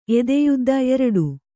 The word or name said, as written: ಎದೆಯುದ್ದ